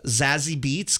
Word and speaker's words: Zazie Beetz: Uh, Zazie Beetz, gotta be Zaz- Zazie, Beetz.